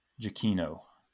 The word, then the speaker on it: Giacchino: Like you said, Michael Giacchino, I think is how you pronounce his name. He has it in his Twitter bio because because I'm sure people mess it up constantly.